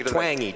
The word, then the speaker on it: Twenge: It's Twangy. I asked her how to pronounce her name. Okay. She told me Twangy. – All right, I'll take it. – Twangy, just like Twangy. From the South.